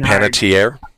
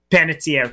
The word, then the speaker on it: Panettiere: It's always been Panettiere. – Panettiere, thank you.